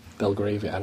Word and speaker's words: Belgravia: Belgravia, I don't know how to pronounce. – That's about right. – That place. – Yeah. – The Bee Land. – That's pretty much it.